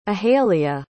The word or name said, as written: ahelia